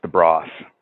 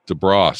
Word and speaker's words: DeBrosse: I have to correct you though. The last name is pronounced DeBrosse. DeBrosse. sorry. E is silent.